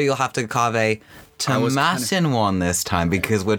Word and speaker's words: Tomatin: Yes, so, Tomatin. It rhymes with satin. That's how you remember Tomatin.